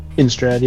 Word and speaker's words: Innistrad: Innistrad, yeah.